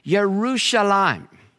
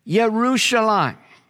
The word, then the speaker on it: Yerushalayim: You might know that if Jesus was pronouncing this, as he no doubt did, probably in Aramaic, which is a sister language to Hebrew, he probably said it something like Yerushalayim, Yerushalayim.